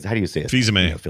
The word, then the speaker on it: Fils-Aimé: I was going back and reading some articles, and you remember Phil's Ame. How do you say it? – Fils-Aimé. – You know, Reggie Fils-Aimé. – Phil's, there you go.